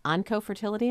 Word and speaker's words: oncofertility: And I know, Dr. Sroga, you're working with this in the field, oncofertility.